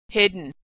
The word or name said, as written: hidden